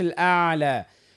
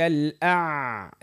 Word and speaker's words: الأعلى: لا ننطقها حرف العين. ما نقولش الأعلى. قولوا هكذا سبح اسم ربك الأعلى.